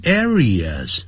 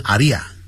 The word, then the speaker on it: areas: Unfortunately, even today, 甚至在今天 in some areas, 啊，不要念成阿利亚，我在上海听到有一个人念成阿利亚，听得蛮难过的。